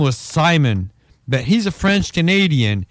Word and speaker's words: Simon: He was drunk as fuck, and he was like, "Hey, because the name Simon in French is pronounced Simon."